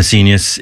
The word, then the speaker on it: Seignius: It's I'm an S-E-I-G-N-U-S. I'm a Senius. It rhymes with genius. I'm an S-E-I-G-N-I-U-S.